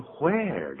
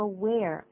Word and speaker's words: where: 我们现在学的是标准的美语，所以一开头用 where，但是我们在字典里头都是念成 where，对不对？ – 有，字典是这样子标，可是现在大部分的不管是英国人或是美国人都念作 w 的音，念作 where，而不念作 where，少数的人还是有这样子念，就是就好像 what 而不念成 what。